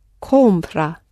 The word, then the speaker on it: compra: And the stress, it's a present tense verb, the stress is on the penultimate syllable. – Compra.